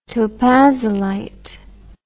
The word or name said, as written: topazolite